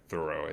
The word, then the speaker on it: Thoreau: I even think about like Thoreau, right? Or Thoreau, as it's actually pronounced.